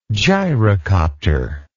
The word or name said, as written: gyrocopter